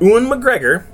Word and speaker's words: Ewan McGregor: Well, it was just recently released that Ewan McGregor, yes, I said his name correctly, is doing the voice for Jiminy Cricket.